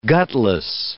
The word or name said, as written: gutless